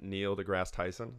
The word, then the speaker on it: Neil deGrasse Tyson: So it was from a podcast that Neil deGrasse Tyson, is that how you say his name? – Mm-hmm.